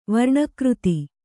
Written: ವರ್ಣಕೃತಿ

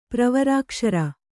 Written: ಪ್ರವರಾಕ್ಷರ

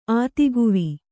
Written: ಆತಿಗುವಿ

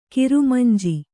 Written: ಕಿರಮಿಂಜಿ

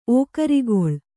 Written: ಓಕರಿಗೊಳ್

